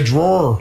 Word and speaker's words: drawer: You ruined the drawer. – Okay. – Or how do you say drawer? – Draw.